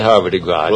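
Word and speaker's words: Havre de Grace: It's because I remember the old racetrack, and it was pronounced, they called it Harvard de Gras. – Well, that's how they named the town. Maryland's Harvard de Gras, too.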